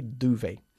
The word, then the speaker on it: duvet: Es una palabra francesa y por eso no pronunciamos la T final, ¿ok? Duvet.